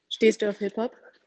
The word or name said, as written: Hiphop